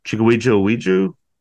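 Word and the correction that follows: Chukwudi Iwuji: Chikwudi Iwuji.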